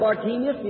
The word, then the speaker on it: Bartimaeus: We know him in the Bible as Bartimaeus. Many pronounce it Bartimaeus. Either way will be all right.